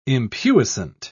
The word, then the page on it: impuissant: British English